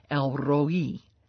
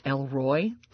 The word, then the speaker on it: El Roi: I got to confess to you, for years I pronounced this as Elroy. And my husband, who has a PhD in Hebrew, said, honey, Elroy is the kid with the Jetsons. So it's not Elroy.